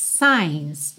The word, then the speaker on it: signs: Depois que volta ao refrão, red lights, stop signs. Signs, esse G é mudo, tá?